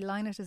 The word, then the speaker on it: Lynott: I'm supposed to say Lynott is the correct pronunciation, but I haven't mastered it in years.